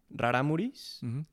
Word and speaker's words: Rarámuri: It's very interesting because Rarámuris live in the Tarahumara mountain range. The mountain range is called Tarahumara, but their ethnicity is Rarámuri. So it's just a mis... how do I say this? It's just... you don't say Tarahumara as a person. Like, he's not a Tarahumara, he's a Rarámuri.